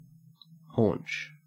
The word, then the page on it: haunch: Australian English